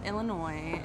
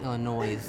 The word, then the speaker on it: Illinois: I grew up in Illinois, in the country. – Wait, no, sorry, it's actually pronounced Illinois.